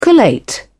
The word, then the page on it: collate: British English